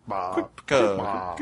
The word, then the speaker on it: Ciupka: Yeah, how do you pronounce C-I-U-P-K-A? – I don't know. – Quickpa. – Quickpa.